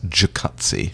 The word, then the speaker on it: jacuzzi: By the way, I just like to use the correct English pronunciation. Yeah. It's jacuzzi. – Jacuzzi.